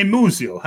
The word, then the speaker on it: Muzio: And hey, Muzio, how you doing? – He said it right.